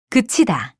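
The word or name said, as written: 글자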